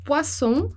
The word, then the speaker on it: poisson: E eu dei o exemplo nas aulas anteriores, do poisson, né, que é o peixe, e do poison, que é o veneno. Então, ficar bem ligado nessa pronúncia dos dois S e do um S, porque muda o som, muda o sentido da palavra, né?